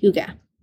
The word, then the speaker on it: hygge: So H-Y-G-E-E, Hygge.